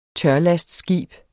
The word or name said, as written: tørlastskib